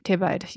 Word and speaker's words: Thébaïde: Tebide? – Tebide? – Tebide. You don't pronounce the E with French.